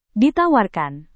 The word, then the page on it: ditawarkan: Indonesian